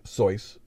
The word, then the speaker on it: Seuss: Uh, Dr. Seuss pronounced it his name Seuss, but he he gave up after a while. That's the that's the German pronunciation.